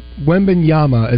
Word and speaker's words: Wembanyama: Wembanyama is how you pronounce the name.